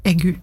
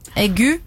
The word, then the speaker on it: aigu: Aigu? Er det sånn du uttales? – Nei. – Nei. – Egu. – Egu? Mm. Ok.